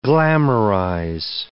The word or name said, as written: glamourize